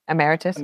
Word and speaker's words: emeritus: emeritus? – Emeritus. Yeah. Am I saying that correctly? – Correct.